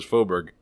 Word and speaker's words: Faubourg: Faubourg? Really? Ooh, with the hard G at the end? – So says Google. The Google lady says Faubourg.